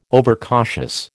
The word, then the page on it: overcautious: American English